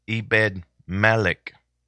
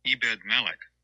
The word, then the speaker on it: Ebed-Melech: I knew I would say it wrong. It's Ebed-Melech. Ebed-Melech. Here, let's listen to it again. – Ebed-Melech.